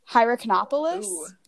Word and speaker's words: Hierakonpolis: There's also a couple articles that cite an ancient city called, we're going to see if I can pronounce this, Hierakonpolis. – That sounds good. – Maybe. – I think that's correct.